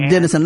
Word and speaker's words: Dinezon: Jacob Dennison. – Jacob Dennison. Let me spell that name out for them. Jacob, D-I-N-E-Z-O-N.